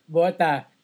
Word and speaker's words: Bota: Bota. And guys, it's spelled like it sounds. – Yeah. – Bota. Yung ginagamit nyo kapag bumabaha sa labas. – Yeah, exactly. – Bota. – Like the rain boot.